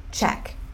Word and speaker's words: check: Check. Uma sílaba só.